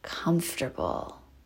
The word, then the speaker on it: comfortable: But literally, once we're relaxed, let's just repeat the affirmation, I am comfortable. I am comfortable. I am comfortable. Comfortable is weird. We really take that syllable out of there.